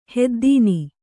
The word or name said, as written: ಹೆದ್ದೀನಿ